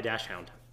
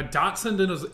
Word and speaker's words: Dachshund: It's spelled Dachshund. – No. Oh, wait. – A Dachshund is a wiener dog. – Maybe I just said it wrong. It's Dach, no S. UND.